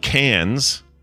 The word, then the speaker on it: Cairns: This is C-A-I-R-N-S, right? – Correct, yes. – That if that is spelled Cairns, then that's effed. – it's pronounced Cairns. Oh, yeah, Cairns.